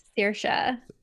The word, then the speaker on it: Saoirse: So my name is Saoirse Clare McGovern. – Saoirse. – And, yes. Saoirse.